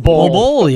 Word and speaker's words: Bol Bol: Bol Bol.